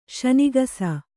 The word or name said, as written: ಶನಿಗಸ